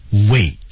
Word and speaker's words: wait: 刚才说的 wait, 急了的时候 T 是不发音的啊，失去爆破，这个是没错的。